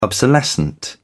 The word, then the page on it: obsolescent: British English